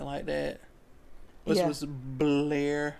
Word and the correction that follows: Blore: Blar. I keep forgetting. – They'd be like, Blore. I was like, how do you spell that? But it's Blore.